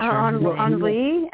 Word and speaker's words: Henri: Did he pronounce it Henry or the French way? I'm not going to butcher. – Henri, and then my mom would call him Rick, and they would change it.